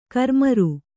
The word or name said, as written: ಕರ್ಮೀರ